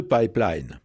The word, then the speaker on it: pipeline: Même s'il est apparu dans la deuxième moitié du 19e siècle, il a donc près d'un siècle et demi, il a gardé des traces de son origine, ne serait-ce que dans sa prononciation. Pipeline. Alors, on peut franciser, hein. On peut tout simplement prononcer pipeline et le tour est joué.